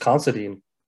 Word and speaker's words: Considine: Constantine. How do I say his last name? I fumble it every time. – I think it's just Considine. – Considine, okay. – Or Considine. – Let's pick, Considine. Considine.